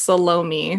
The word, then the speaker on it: Salome: So the daughter is unnamed in the New Testament, but most scholars agree that her name is apparently it's pronounced Salome, which is stupid because it sounds like salami.